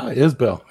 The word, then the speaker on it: Isbell: I hope to God I said your name right. – No, Isbell. That's great.